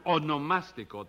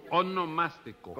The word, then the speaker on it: onomástico: Su onomástico, Tres Patines. – Sí, eso mismo, su monoelástico. – No, su onomástico.